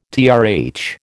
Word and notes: TRH: American English

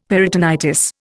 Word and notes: peritonitis: Indian

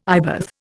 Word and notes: eyebath: Indian

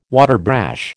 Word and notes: waterbrash: American English

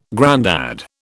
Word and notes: grandad: British English

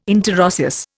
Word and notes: interosseous: Indian

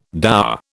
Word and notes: dower: British English

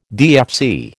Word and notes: DFC: American English

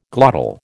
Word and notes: glottal: American English

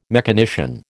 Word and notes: mechanician: American English

